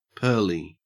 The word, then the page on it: pearly: Australian English